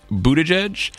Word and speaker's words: Buttigieg: The mayor of South Bend, Indiana, Pete Buttigieg, and the chair of the South Carolina Democratic Party, Jamie Harrison.